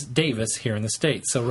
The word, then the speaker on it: Davies: It's Davis.